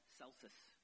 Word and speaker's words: Celsus: There was a Greek scholar in the second century named Celsus. I'm sorry, I said his name wrong. Celsus. And he was a second century Greek philosopher who hated Christianity and wrote articles disputing Christianity.